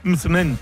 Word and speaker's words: msemen: Pero una palabra complicada decía es M-S-E-M-E-N. Midsmen. Midsmen, ¿vale?